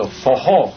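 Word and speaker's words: forró: And this was the popular music played in the Northeast for those dances for all, and slowly it became Fojó, spelled F-O-R-R-O. The R's are pronounced like an H, of course, so Fojó.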